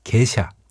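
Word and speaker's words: Gesha: 에티오피아 남서부 카파 지역에 있는 게샤. 그렇죠. 제가 아까 언급했죠. 게샤라는 지역 이름에서 비롯됐는데요. 이곳에서 발견된 커피 품종이 다른 나라로 전파되는 과정에서 게샤라는 발음이 일본의 게이샤와 비슷하게 읽히면서 오늘날의 게이샤 원두가 됐다고 합니다.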